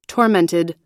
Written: tormented

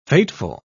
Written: fateful

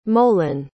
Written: molan

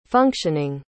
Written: functioning

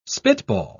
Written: spitball

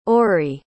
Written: ory

